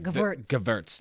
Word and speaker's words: Gewürztraminer: Well, I think if you said Gewürztraminer, that would be okay. But I think if you do the German, it'd be like Gewürz. Yes. – Gewürztraminer.